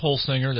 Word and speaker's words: Holsinger: Last name is Holsinger,